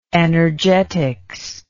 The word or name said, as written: energetistic